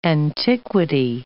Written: antiquity

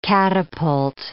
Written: catapult

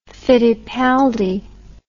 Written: fittipaldi

